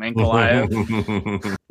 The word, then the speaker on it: Ankalaev: Then it's going to be March 12th, UFC Fight Night Santos versus Ankalaev. I think I said that right this time, Ankalaev.